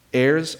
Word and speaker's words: heirs: Heirs of God and co-heirs with Christ. Now, kids, I'm not mispronouncing heirs, okay? I'm not saying that wrong. Heirs.